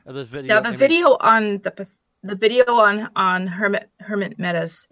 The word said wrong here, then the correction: Hemet: Hemet, not Hermit.